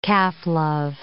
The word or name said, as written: calf-love